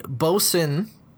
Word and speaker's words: Boatswain: It is called the Boson Double IPA. That's spelled Boatswan.